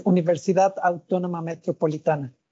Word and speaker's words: Universidad Autónoma Metropolitana: Yeah, it's Universidad Autónoma Metropolitana.